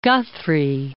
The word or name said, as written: Guthrie